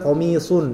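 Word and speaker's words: Qamis (قميص): Qamis. Baca sotnya itu dengan tidak tanpa menyongkan bibir, enggak usah menyongkan bibir. Qamis. Jadi kalau misal sot itu dia menyong bibir ketika dhammah saja. Seperti antum bilang qamisun.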